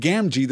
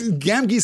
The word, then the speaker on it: Gamgee: My audiobook pronounces the second G in Gamgee the same as the first.